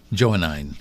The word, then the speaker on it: Johannine: Johannine. – Johannine. All right. Johannine. – Johannine. – And so could you share what that means? – Well, the Johannine is John, the writings of John. So Johannine corpus, they would call it, are the writings of St. John, the evangelist.